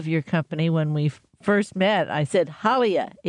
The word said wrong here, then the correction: Halia: H-A-L-I-A, and I was like, no, it's Halia, which is Hawaiian.